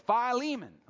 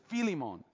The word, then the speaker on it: Philemon: the most mispronounced book of the Bible. Everybody's like, Philemon. I'm like, no, only if you're in Texas. No offense to those of you from Texas, but it's Philemon.